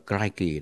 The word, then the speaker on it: crikey: Tôi đã check với người Úc thì đọc là crikey đó.